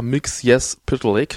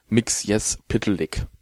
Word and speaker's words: Mxyzptlk: In World's Funnest geht es um die beiden Figuren Batmite und Mr. Mix Yes Pitlick. So spricht man diesen Namen aus. Man spricht ihn, oder man schreibt ihn MXYZPTLK, aber geschrieben, ausgesprochen wird er tatsächlich Mix Yes Pitlick.